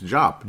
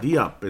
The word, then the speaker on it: Diop: It's Jop. Do you remember Desogna Jop? Yeah, I know. That's a real easy name to pronounce and everything. But the name's been out there before. It's Jop. It's not Diop.